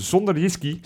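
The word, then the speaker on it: SønderjyskE: En die heeft de 2-0 gemaakt, wat uiteindelijk 2-1 gewonnen wedstrijd tegen zonder whisky. – Dat spreek je lekker uit. – Lekker, nog een keer. – Zonder whisky. – Huh? – Ja, klinkt als zonder whisky, maar dan op z'n Deens.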